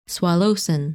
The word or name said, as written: Sualocin